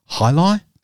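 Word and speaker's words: jai alai: Hey, Dirty, have you heard the story about the fastest projectile speed game called Hi-Li, spelt with a J? – Is that like Hi-Lo milk?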